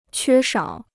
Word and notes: 缺少: quē shǎo

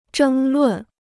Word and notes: 争论: zhēng lùn

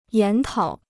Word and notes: 研讨: yán tǎo

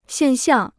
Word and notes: 现象: xiàn xiàng

